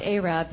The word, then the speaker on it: Arab: and it's pronounced Arab, but it's spelled Arab, A-R-A-B.